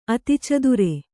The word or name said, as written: ಅತಿಚದುರೆ